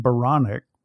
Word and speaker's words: Baronic: And my last name, Baronic, rhymes with bionic.